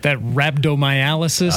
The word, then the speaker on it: rhabdomyolysis: Is that rhabdomyolysis? – That's exactly what that is.